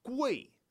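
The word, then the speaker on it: Gui (贵): So those are the three props to remember the character for expensive, and it's pronounced Gui. So that means that you're going to have Gu and A, and fourth tone is in the bathroom.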